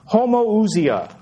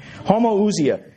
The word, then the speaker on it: homoousia: The word is homoousia. I'll spell it for you. Again, I don't have a transparency. Homoousia. This is Greek. H-O-M-O-O-U-S-I-A. H-O-M-O-O-U-S-I-A. Homoousia.